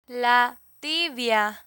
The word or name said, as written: la tibia